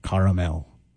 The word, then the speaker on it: caramel: There's an A in the middle when you're talking about the ingredient, caramel. It's three syllables there.